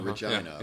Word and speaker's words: Regina: Regina. – Regina is what it is. Is it Regina? – Oh, Regina. Saskatchewan, right? – Oh, it's really Regina. – Yeah, Regina.